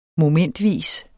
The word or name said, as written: MOMENTVIS